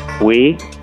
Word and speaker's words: Oui: Oui. – Oui is right.